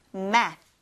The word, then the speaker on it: math: math, math, math. Very good. th 발음으로 끝나니까 math 한 다음에 이제 혀를 윗니, 아랫니 사이에다 이렇게 넣어서 math. 발음을 해주셔야 돼요.